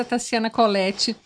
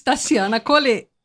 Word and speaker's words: Tassiana Colette: Eu falei minha vida inteira Tassiana tá Colette, mas eu passei o Natal com a minha família gaúcha e eles me convocaram, me intimaram. Vamos falar direitinho a pronúncia do nosso nome? Vamos uniformizar? uniformizar? Então agora, por favor, eu também, para mim, estou falando pela primeira vez Tassiana Colette.